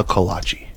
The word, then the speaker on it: kolache: Well, now my good thing is I Googled it for the right way to say it, and it says kolache is technically the plural form of the word. – Thank you. – However, as time has passed, the original pronunciation has been dropped by many people, and it is a kolache.